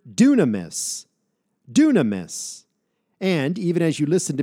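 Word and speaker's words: dunamis (δύναμις): Doesn't the first part of that word sound somewhat like the English word dynamite? We get our English word dynamite from this Greek word dunamis.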